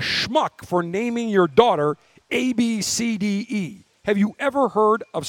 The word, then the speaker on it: Abcde: Abcade? – The mother said it's pronounced Ab-City. A-B-C-I-T. Ab-City. A-B-C-D-E.